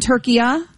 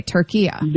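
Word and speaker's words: Türkiye: And so now the instruction is that also in English, say it like how it's essentially supposed Turkiya. – To be pronounced. Okay, Turkiya. – There you go. That's perfect. That's how it's pronounced.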